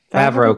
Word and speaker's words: Favreau: Release the Favreau cut, or however you say his name. – Favreau cut.